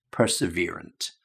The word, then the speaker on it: perseverant: And just be careful again with that pronunciation, perseverant.